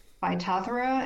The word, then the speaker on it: Phytophthora: Well, Phytophthora. It's pronounced Phytophthora, and the word Phytophthora means plant destroyer.